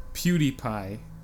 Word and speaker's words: PewDiePie: PewDiePie.